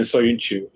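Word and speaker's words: Söyüncü: Chalar is his first name. That's how you pronounce it. And then Soyuncu is his last name.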